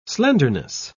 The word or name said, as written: slenderness